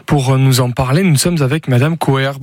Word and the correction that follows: Coheré: Bonjour Madame Coher. – Coheré, oui, bonjour. – Coheré, pardon, excusez-moi pour la prononciation.